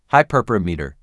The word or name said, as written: hyperparameter